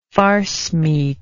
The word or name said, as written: farcemeat